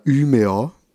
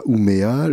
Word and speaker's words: Umeå: Oui, j'étais à Umeå, d'ailleurs ça se prononce comme ça, euh, et pas Umea. Le U donc en, suédois se prononce U, et euh, le A, vu qu'il y a un petit rond au-dessus, ça le transforme en euh, A un peu comme le A des ch'tis, si vous voulez.